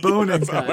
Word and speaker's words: Bonenkai: Wait, say it again. A Bonenkai? – Yeah, that's exactly how you pronounce it, Matt. Bonenkai.